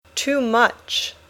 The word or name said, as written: too much